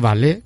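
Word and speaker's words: Valais: El cantón de Valle, que me lo, he estudiado para pronunciarlo bien, se dice como, como el baile.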